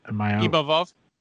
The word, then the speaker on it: Imavov: How do I say his last name? – Imavov. – Imavov. That's right.